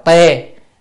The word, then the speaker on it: thê: Thê thần tức là chữ thê á, chữ thê đọc là tê, ở đây á, đọc âm tê thê là tê cũng được, là cái chỗ nghỉ dừng lại của hàng bậc thánh.